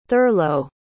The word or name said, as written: thurlow